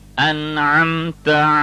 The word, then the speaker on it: أنعمت: Because you violated the principle of not pronouncing the ayn. It is like you're mixing the noon and the meem. A namta. Actually, it is an amta. Ayn must be completely clear.